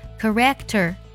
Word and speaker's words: character: Good character, character 这个单词呢是性格的意思。我听过好多同学把这个单词读作 character 啊，这样读是错的。它的重音呢在第一个音节，char character 要这么去读。